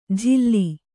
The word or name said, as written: ಜಿಲ್ಲಿ